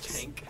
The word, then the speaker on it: Cenk: Cenk. – Cenk. – Cenk. – Cenk. – What are you doing, Cenk? You're a mess. I hate Cenk. – God damn it, Cenk.